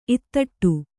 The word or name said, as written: ಇತ್ತಟ್ಟು